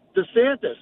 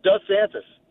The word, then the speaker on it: DeSantis: I really, really, really think that the reason he's doing it is because most of my friends and I actually pronounce it DeSantis.